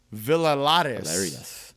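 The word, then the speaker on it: Villarías: We've got Carlos, and this is how you pronounce the last name, Villarius. – Is that it? – Villarius, I believe.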